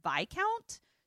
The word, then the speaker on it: Viscount: Viscount also is spelled discount, but with a V, but it's pronounced Viscount.